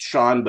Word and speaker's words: Chaon: Yeah, and I just want to let your listeners know that it's pronounced Sean, but it's spelled C-H-A-O-N.